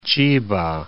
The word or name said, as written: Chiba